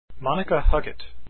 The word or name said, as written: huggett